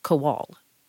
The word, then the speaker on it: Kowal: Technically speaking, the emphasis on my last name should be on the second syllable, Kowal.